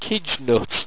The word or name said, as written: qidgnut